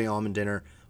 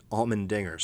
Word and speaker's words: Allmendinger: Allmendinger, 129. Allmendinger, sorry, mispronounced his name there.